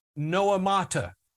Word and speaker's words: noemata: The word devices is the Greek word noemata. It's a compound word, and let me spell it for you. It's N-O-E-M-A-T-A. N-O-E-M-A-T-A.